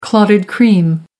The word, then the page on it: clotted cream: American English